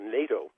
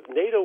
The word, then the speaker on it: NATO: It rhymes with potato, and that's about all I know about it. Oh, well, let's go down memory lane a little bit here. NATO was responsible for the dismemberment of Yugoslavia.